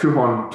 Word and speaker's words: Tuhon: Tuhan.